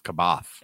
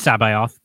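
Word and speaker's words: C'baoth: I thought it was Kaboth. – It's Sabaoth. That's how the audiobook pronounces it in the second version of the audiobook,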